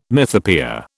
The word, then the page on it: mythopoeia: British English